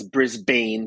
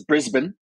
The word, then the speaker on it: Brisbane: So it's doing all these preparations, and that is the city of Brisbane, which some people mispronounce as Brisbane because that's how it looks. But it's Brisbane, and it's on the east coast of Australia in Queensland, sort of like in the middle of the coast.